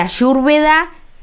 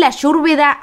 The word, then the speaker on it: Ayurveda: Ellos la Y la pronuncian como sería Ayurveda, no Ayu, como hacemos nosotros, que hacemos el Sh, sino sí, que Yu, como Ayurveda.